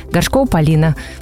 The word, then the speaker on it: Горшкова Полина: серебряный призер Олимпийских игр Горшкова Полина. Полина, привет! Добрый день! – Все правильно сказала? – Да, все верно.